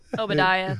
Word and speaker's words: Obadiah: Obadiah, thank you.